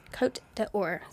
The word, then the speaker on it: Côte d'Or: Côte is C-O-T-E. D'Or is D apostrophe O-R. – Okay. – Côte d'Or.